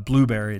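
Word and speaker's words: Blubrry: Now, there's another pretty great one out there that a lot of people use called Blueberry. It's not spelled like Blueberry, but if you Google Blueberry hosting, you'll find it.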